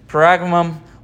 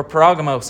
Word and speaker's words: Pergamum: Both pronunciations are correct. Pergamum or Pergamos.